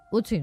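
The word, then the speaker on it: Ötzi: Otzi, creo que se llamaba, un nombre muy, muy noruego. – Utsi, ¿no? – Utsi, pues sí, Utsi.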